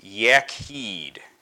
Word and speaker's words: Yachid: it's pronounced Yachid, and that is spelled Y-A-C-H-I-D. Y-A-C-H-I-D.